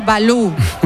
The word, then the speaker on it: Valou: Este Balú es con V, ¿eh? No es como el oso. – Sí, Yanis Balú. V-A-L-O-U.